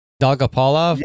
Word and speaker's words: Dolgopolov: Dogopolov, yeah. Yeah, yeah. – Can you say his name for me? – Dolgopolov, yeah. That's how I say it.